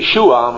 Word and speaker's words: Yeshua: Joshua in the Hebrew, Yeshua, and the way they pronounce, the Jews pronounce it today, Yeshua, Mashiach, Yeshua, Yeshua, the word Joshua, the Savior, it means to save.